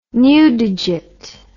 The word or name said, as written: newdigate